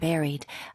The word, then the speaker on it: buried: Buried. Spelling은 B-U-R-I-E-D. – 네, 그런데 희한하게 buried가 아니네요. – 맞아요. – 발음 자체가. – 발음은 약간 A로 생각하면서 발음하면 okay.